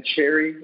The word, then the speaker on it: Cherry: It's Korey, and then cherry, like the fruit.